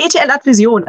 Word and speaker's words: ETL Advision: Nee, ETL Advision, also die deutsche äh, Aussprache ist es.